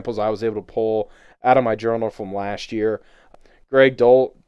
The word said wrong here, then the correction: Greg Dulcich: I'm going to butcher his name all season. Greg Dulcich, he looks like baby Kelsey.